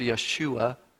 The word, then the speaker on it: Yeshua: The Hebrew word behind the word salvation here is pronounced Yeshua.